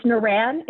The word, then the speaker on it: Naran: It's Naran. It's spelled Naran. It's never going to be like very straightforward, but thank you for even asking.